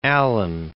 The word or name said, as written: Alleyne